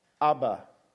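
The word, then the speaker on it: Abba (אבא): And it's pronounced Abba. It says it comes from the pronunciation of a child trying to say father.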